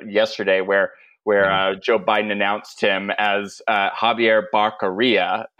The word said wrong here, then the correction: Becerra: and then, Javier Becerra, and he couldn't even get his name right.